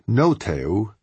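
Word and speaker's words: No Tae-woo (노태우): Plus, there was the non-Kim candidate, Roh Tae-woo, pronounced No Tae-woo and called Just Say No by the Foreign Press Corps.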